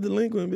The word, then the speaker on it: DLEQUINT: It's pronounced delinquent, bitch.